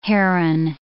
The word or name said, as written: heron